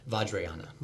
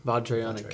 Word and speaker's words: Vajrayana: Vajrayana. – Vajrayana.